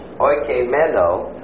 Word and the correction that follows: oikoumene: Oikeimene. That would be O-I-K. Whoops, I still didn't spell it right. O-I-K-O-U-M-E-N-E with a long bar over the last E. Oikoumene, which means the inhabited world.